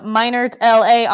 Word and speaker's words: Meinertla: I am Meinert LA on both.